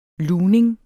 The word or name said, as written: lugning